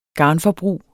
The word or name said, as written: garnforbrug